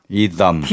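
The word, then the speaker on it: Etham: 이담. TH니까 이담. 번데기 발음인가요? 번데기인 것 같아요. 이담.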